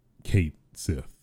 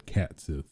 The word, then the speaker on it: Cait Sith: which has been confirmed to be the official pronunciation of Kate Sith, technically. I always said Kat Sith, but technically, according to Square, the developers of the game, it is Kate Sith.